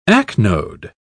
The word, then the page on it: acnode: British English